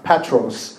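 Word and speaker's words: Petros (Πέτρος): 那个希腊文的读音就是彼得 Petros。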